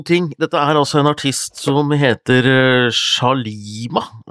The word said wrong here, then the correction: Slimane: Um, akkurat hvordan du uttaler det er jeg litt sånn usikker på. Uh, Slimane. Slimane, mener jeg.